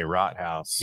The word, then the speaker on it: Rothaus: I mean, I think most people say rot house. – It's rot, house.